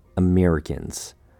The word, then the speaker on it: Americans: For the first word, we have Americans. Four syllables. The first syllable, a, uh, simply has a short A sound. Mirror sounds like ear, like the body part with an M in front. With ri, this is where many people make the mistake of saying re. It's not a long E sound. Make sure to say the short I sound here.